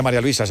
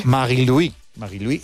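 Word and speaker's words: Marie-Louise: No se dice María Luisa, se dice en su... – Sí. – Marie-Louis Eta, ¿no? – Así es.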